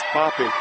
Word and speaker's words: Poppe: And the fifth starter is Andrew Poppy, 6 feet 3 inches tall, spelled P-O-P-P-E, but pronounced Poppy.